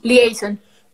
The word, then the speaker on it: liaison: ¿cómo se dice? ¿Liaison? ¿Liaison? ¿Cómo? – Liaison.